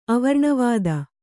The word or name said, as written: ಅವರ್ಣವಾದ